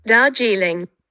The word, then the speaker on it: Darjeeling: So you want to know how to pronounce Darjeeling? Well, there I just said it. Darjeeling. You want me to go a little slowly? It's Darjeeling. Now you don't have any excuse not to ask for this wonderful tea.